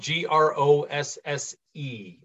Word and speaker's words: Grosse: Look for uh, Patricia Grosse on LinkedIn. That's the easiest way to get to me. – Okay, and that's spelled G R O S S E on the last name.